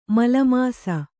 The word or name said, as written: ಮಲೀಮಸ